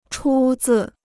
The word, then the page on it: 出自: chū zì